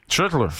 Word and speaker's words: Schlittler: Schlittler. J'aime ça de la manière que tu l'as très bien prononcé.